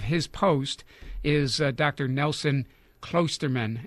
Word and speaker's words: Klosterman: And the last pronunciation was the correct one. It's Klosterman. – Klosterman. I was told that it had a long O, but I am a master at mutilation of last names.